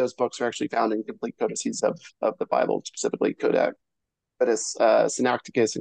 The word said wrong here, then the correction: Sinaiticus: and Codex Vaticanus. I think I'm saying that correctly. – Sinaiticus and Vaticanus. – There you go.